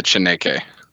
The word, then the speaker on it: Chaneque: That'd be the Chaneque.